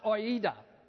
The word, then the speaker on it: oida: It's a word that's phonetically pronounced oida, O-I-D-A.